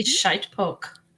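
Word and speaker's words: shitepoke: but it looks like it says shit pokey. – It might say shite poke.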